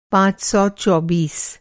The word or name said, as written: पाँच सौ चौबीस